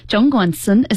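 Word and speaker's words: Zhongguancun: But if you're a foreign resident here in Beijing, working at China's big tech hub, our answer to Silicon Valley, Zhongguansun, is that the correct pronunciation, Brian? – Absolutely correct.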